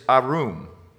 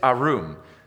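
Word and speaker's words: arum (ערום): The word crafty in Hebrew, which is what the Old Testament was written in, is arum. It's arum.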